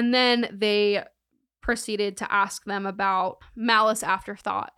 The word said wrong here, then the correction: aforethought: Aforethought is how you pronounce that.